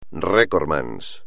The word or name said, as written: recordman